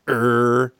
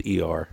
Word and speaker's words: ER: Uh, he appeared in numerous hit shows of the day, including According to Jim, Desperate Housewives, Er, NCIS, and How I Met Your Mother. That could might be pronounced as ER. ER, yeah.